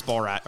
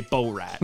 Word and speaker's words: Borat: A lot of people were very proud of me for learning how to pronounce Borat. Or should I say Borat? – No, you should not, because that's incorrect.